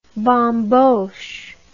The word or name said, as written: bamboche